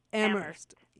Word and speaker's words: Amherst: No H sound. Amherst. – Amherst.